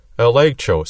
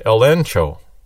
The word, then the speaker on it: elegcho: So even though it's spelled elegcho, you actually pronounce it elencho.